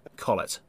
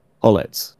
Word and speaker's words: Collet: How do I pronounce this? Xiao? Kao? Kao? The Brazilian. Collet. – Ollett, yes.